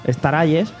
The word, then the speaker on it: Star Allies: Star Allies.